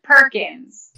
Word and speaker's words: Perkins: Perkins. – Caroline Perkins. Oh, my gosh. – See, I don't even have to spell it. It's like the restaurant.